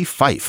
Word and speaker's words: Phyfe: and it's written by one William Henry P. Fife, Fife spelled P-H-Y-F-E, which always looks to me kind of like Fife.